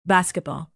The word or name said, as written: basketball